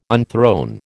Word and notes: unthrone: American English

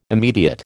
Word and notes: immediate: American English

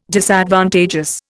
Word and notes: Disadvantageous: Indian

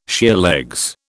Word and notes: sheerlegs: British English

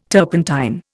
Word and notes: turpentine: Indian

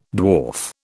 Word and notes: dwarf: British English

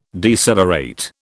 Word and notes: decelerate: British English

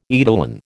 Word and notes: eidolon: American English

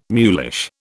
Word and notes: mulish: British English

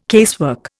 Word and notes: casework: Indian

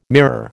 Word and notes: mirror: American English

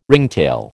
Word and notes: ringtail: American English

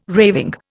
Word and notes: raving: Indian

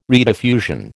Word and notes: rediffusion: American English

